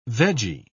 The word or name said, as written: veggie